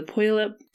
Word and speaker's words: Puyallup: Puyallup Fairground.